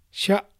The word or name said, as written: Sha